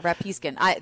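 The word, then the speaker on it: Rapiscan: Sure, Rapiskin.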